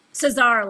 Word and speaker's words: Cesar: I'm joined by Dr. Christian Payne, Kat Cesar. Did I pronounce it properly? – Cesar, like bizarre.